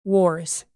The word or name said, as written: wars